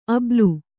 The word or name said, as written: ಆಭೀಲ